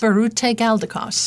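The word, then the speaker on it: Biruté Galdikas: Berute Galdikas.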